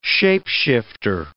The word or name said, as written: shape-shifter